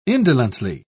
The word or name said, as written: indolently